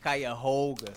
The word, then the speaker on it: Cuyahoga: Cuyahoga.